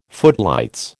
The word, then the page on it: footlights: American English